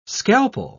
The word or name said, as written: scalpel